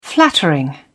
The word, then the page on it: flattering: British English